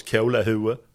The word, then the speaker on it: kolahuvud: Det uttalas kolahue.